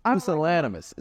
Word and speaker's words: pusillanimous: Pus- pusillanimous. It's pusillanimous.